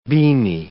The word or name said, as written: beamy